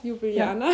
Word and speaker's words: Ljubljana: Ju Ljubljana. ja. War richtig, ja? – Ljubljana. Ja.